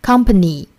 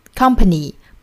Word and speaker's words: company: Company， company，不要把它读成 company，应该是 company。